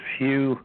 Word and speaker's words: Hugh: But my name is Hugh, H-U-G-H, a last name Charlson, is spelled T like Tom, R-A-U-L-S like Sam, E like Edward, N like Nancy.